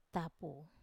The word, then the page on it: tapu: Wamesa